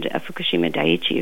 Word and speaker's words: Fukushima Daiichi: and Fukushima Daiichi, if you say it correctly.